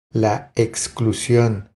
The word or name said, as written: La exclusion